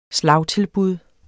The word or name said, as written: slagtilbud